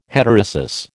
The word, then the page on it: heterosis: American English